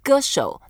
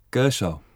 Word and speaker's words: 歌手: So let's say 歌手, that means singer. – 歌手. – 歌手, you are a singer.